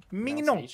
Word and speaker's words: Mignon: Or like Filet Mignon? – Yeah, sort of. – Like Louis Mignon. Pronunciation? – Mignon.